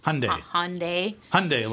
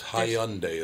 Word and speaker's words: Hyundai: It's Hyundai is how it's spelled. – Hyundai. – But they pronounce it like Sunday. So Hyundai, yeah, Hyundai.